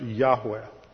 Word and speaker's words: Yahweh: The personal name for God in the Old Testament was based on the sacred tetragrammaton, which was written YHWH with no vowels and usually thought to be pronounced Yahweh.